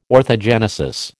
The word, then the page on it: orthogenesis: American English